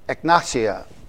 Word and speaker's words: Egnatia: There's a Egnatia is how you pronounce it, the via.